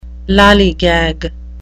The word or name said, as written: lallygag